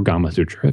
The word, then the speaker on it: Gamasutra: it's a, a Gamasutra article or Gamma Sutra, if you want to pronounce it that way.